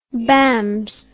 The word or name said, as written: bambs